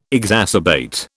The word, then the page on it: exacerbate: British English